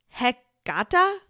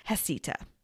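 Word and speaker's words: Heceta: It looks like it should be pronounced Hecata, It's H-E-C-E-T-A. Thank goodness for those videos on YouTube, or I'd probably be mispronouncing it this entire episode, but it's Hesita.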